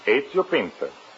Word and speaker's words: Ezio Pinza: Is that the way you pronounce it? – That is right. Ezio Pinza. – How's that again? – Ezio. E-Z-I-O. Ezio. Pinza. P as in Peter, I-N-Z-A. Ezio Pinza.